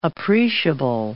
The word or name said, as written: appreciable